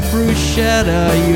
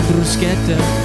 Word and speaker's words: bruschetta: Although you were born and raised to pronounce it bruschetta, you're wrong. Just know that it is actually bruschetta.